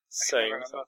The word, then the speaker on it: Sané: Is it Sane or Sané? – Sane. They pronounce it as Sane.